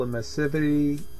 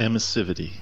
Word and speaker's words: emissivity: And one of the things that they've been looking at is sort of Hall emissivity. I probably said that wrong. Emissivity? Emiss- yeah. Emissivity. – yeah, I said it right.